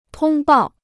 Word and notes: 通报: tōng bào

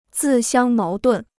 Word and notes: 自相矛盾: zì xiāng máo dùn

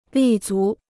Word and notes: 立足: lì zú

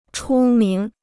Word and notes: 出名: chū míng

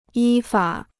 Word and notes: 依法: yī fǎ

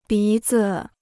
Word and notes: 鼻子: bí zi

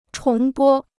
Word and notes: 重播: chóng bō

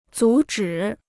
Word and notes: 阻止: zǔ zhǐ

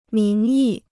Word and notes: 名义: míng yì

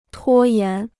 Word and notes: 拖延: tuō yán

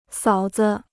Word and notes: 嫂子: sǎo zi

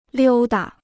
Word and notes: 溜达: liū da

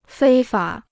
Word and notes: 非法: fēi fǎ

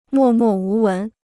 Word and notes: 默默无闻: mò mò wú wén

